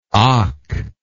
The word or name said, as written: akh